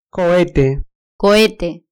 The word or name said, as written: cohete